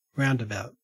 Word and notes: roundabout: Australian English

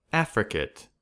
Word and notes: affricate: American English

